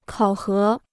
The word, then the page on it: 考核: kǎo hé